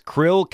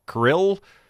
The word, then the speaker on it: Kirill: And then it's like K-I-R-I-L-L. And I thought, is that Kryl? Is it Kryl? Like the little animals in the sea? Or is it Kryl? Like two syllables? Or is it kind of a syllable and a half? Where the first I is it, you know, tell you what, if it rhymes with thrill, you're probably right.